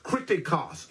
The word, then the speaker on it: kritikos: It's pronounced kritikos. It's spelled K-R-I-T-I-K-O-S.